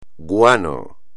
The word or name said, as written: guano